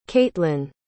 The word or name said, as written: catlyn